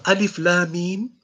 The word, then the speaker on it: Alif Lam Mim: If you pronounce Alif Lam Mim, you will find that the alif comes from the throat. Ah. The lam comes from the palate or the tongue.